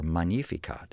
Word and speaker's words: Magnificat: Magnificat, or Magnificat, if you'd like the Latin pronunciation, Italian-Latin pronunciation.